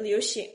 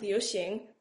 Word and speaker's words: Liu Xing: Actually, the song Yellow, I read a little article about John Chu's thinking a little bit behind like making it Liu Xing. I totally butchered that. Liu Xing,